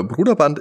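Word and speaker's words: Brøderbund: Ich habe das jetzt auch nochmal gegoogelt und äh, Bruderband ist wohl die korrekte Aussprache.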